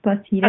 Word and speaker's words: Placides: It's Placides.